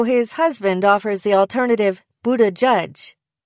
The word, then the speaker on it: Buttigieg: To me, the last syllable sounds most like the word shuzh, meaning to make more attractive and popularized lately by Queer Eye's Jonathan Van Ness. Derwing says that Buttigieg is best because it captures the stresses correctly. Using three words to demonstrate how it's supposed to sound gives the impression that all three syllables should be emphasized equally.